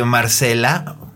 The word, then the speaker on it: Marcella: O sea, se escribe Marcella con doble L, pero se pronuncia Marcella a, la italiana.